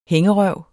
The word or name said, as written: hængerøv